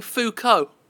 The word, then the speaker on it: LeBeau: Roman LeBeau pronounces his surname Foucault. – Roman Foucault? – Or car, I guess. Foo-K-O-H. Foucault. But it's spelled L-E-B-E-A-U.